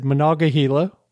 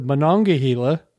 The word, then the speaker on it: Monongahela: And one river that he said four times, he said Monongahela, which is the Monongahela.